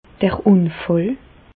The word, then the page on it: d'r Ùnfàll: Bas-Rhin; Reichshoffen